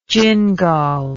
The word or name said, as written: jingal